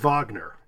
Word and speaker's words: Wagner: The thing that gets me, though, is there's a college in New York that's named after Wagner, and it's spelled W-A-G-N-E-R, but it's pronounced Wagner. – Oh, really? – So then you really mm-hmm. get, like, yeah, it's Wagner College. It's not Wagner College.